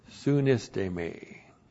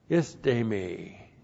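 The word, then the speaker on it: sunistemi: And then the verb sunisteme. Looks like that in the Greek, S-U-N-I-S-T, long E-M-I, sunisteme.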